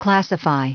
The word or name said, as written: classify